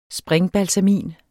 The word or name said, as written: springbalsamin